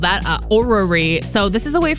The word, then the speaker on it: Orrery: So this one's hard to pronounce. So it's O-R-R-E-R-Y. – It's Orrery.